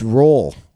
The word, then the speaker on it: Roald: It's Ronald Dahl. – It's Roald. I think. – Yeah, but it's spelt like Ronald. – R-O-A-L-D. Roald Dahl? I think it's R-O-A-L-D.